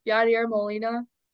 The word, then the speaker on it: Yadier Molina: I'd say, I think his first, I could completely butcher his first name. Yadier Molina. – No, that's perfect.